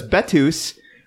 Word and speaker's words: Bettws: Um, well, looked up the pronunciation because Betus uh, in stereotypical Welsh fashion is spelled B E T T W S.